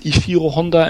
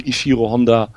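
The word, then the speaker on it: Ishirō Honda: Er ist selber mal gefragt worden und hat sich dann als Ishiro Honda entsprechend äh, tituliert. Also ich würde sagen Ishiro Honda. – Mhm. Das ist schon Betonung auf dem O von Ishiro sozusagen und dann stimmt das schon.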